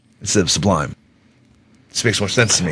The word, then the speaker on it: Sublime: Sublime. But I call them sublime. Like Subway. It's sublime. – You put the accent on the wrong syllable.